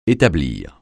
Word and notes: établir: français

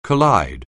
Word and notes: collide: anglais

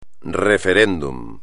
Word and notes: referéndum: espagnol

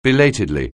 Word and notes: belatedly: anglais

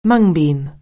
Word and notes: mung bean: anglais

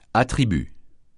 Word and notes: attribut: francais

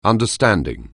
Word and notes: understanding: anglais

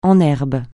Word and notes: en herbe: français

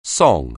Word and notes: song: anglais

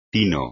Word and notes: tino: espagnol

